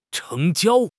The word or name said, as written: 成交